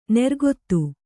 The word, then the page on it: ನೆರ್ಗೊತ್ತು: nergottu